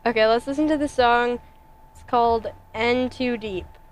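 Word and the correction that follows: N 2 Deep: In Too Deep. It's an N. – Yeah, I know, but like, it's pronounced in.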